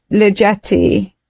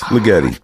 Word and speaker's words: Ligeti: Gyorgy Litoji. How do you say it? – Yes, exactly. – Is that how you say it? – We're gonna go with that. – Gyorgy Ligeti. – Yes, Ligeti.